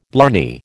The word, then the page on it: blarney: American English